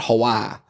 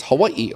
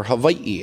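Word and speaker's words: Hawaii (Hawaiʻi): You know, it's not Hawaii. You know, I'm going to Hawaii. You know, it's Hawaii or Hawaii. It's the three syllables.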